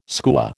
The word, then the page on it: skua: American English